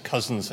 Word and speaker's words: Couzens: My name is Ed Couzens. I know it's spelt a little strangely, but it's just Couzens as in brothers and sisters.